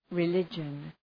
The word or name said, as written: religion